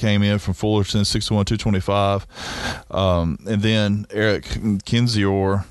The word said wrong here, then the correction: Kinzer: I don't know. – Kinzer. – I don't know how to pronounce it. – His dad corrected me via email last week, and I appreciate the email. Awesome. Kinzer.